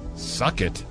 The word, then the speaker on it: Succat: I'll spell it. You tell me how you pronounce it. S-U-C-C-A-T. – S-U-C. – Yeah. Suck it. It's the first time anyone's ever said that on these airwaves, I bet. That's how his last name was.